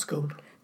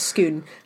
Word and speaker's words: Scone: Not Scone. – No. Skoon is spelt S-C-O-N-E. Like the baked good, but it's not pronounced that way. It's pronounced Skoon.